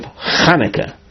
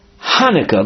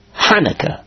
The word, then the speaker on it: Hanukah: Whether that's the actual Ches pronunciation or not is not the point right now, but he would pronounce it Hanukkah, like you find many many say non-Hebrew speakers. You find in English literature, English publications, when they want to refer to that holiday, they spell it H-A-N-U-K-A-H, Hanukkah. So that's what happens with regard to, that was Rabbi Chiya's pronunciation. He had the trouble pronouncing the ches.